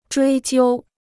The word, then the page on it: 追究: zhuī jiū